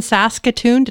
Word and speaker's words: Saskatoon: in Saskatoon.